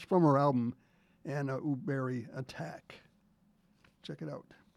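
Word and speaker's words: Högberg: It's pronounced, it's spelled H-O-G-B-E-R-G, but it's pronounced Uberi.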